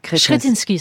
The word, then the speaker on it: Kretinsky: on peut dire Kretinsky ? – Kretinsky, ça se prononce. – Voilà, on peut le dire à la tchèque.